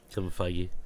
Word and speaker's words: Feige: And that is that Kevin Feige, is that how you pronounce his last name? – Yeah. – I never remember. – Kevin Feige.